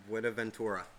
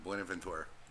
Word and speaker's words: Buenaventura: Buenaventura? – Buenaventura. – Buenaventura.